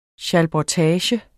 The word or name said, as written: SCHALBURGTAGE